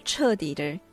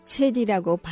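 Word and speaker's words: 처디 (彻底): 나중에 알고 보니 선생님의 중국어 발음이 신통치 않아 처디를 체디라고 발음했던 것이었다.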